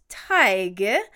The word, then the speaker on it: Tadhg: T-A-D-H-G is pronounced Tig, and I hope I did that right, and D-O-N-N-A-C-H-A is pronounced Dunica.